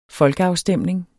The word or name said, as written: folkeafstemning